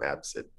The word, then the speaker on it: ABCD: Asdid is his name, or Absid, because it's his name is spelled ABCD. So everyone calls him Absid.